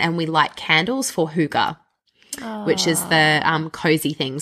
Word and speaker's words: hygge: hygge thing where I clock off at the end of the day.